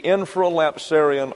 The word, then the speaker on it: infralapsarian: infralapsarian order.